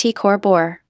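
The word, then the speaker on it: T CrB: The star is called T. Coronae Borealis TCRB, pronounced T. Cor Bor.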